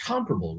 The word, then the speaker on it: comparable: Is it pronounced? It's pronounced comparable, right? – It is. – I say comparable. – You're saying it wrong.